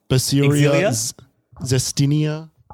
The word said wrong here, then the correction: Zestiria: Oh, sorry, Zestiria.